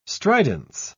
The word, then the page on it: stridence: British English